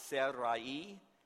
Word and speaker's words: Sarai: We're used to saying it as Sarah, but the way I've been pronouncing it, when it's the R-A-I, is Sarai, and when it's S-A-R-A-H, is Sarah, without the E at the end, but most often we'll just say Sarah.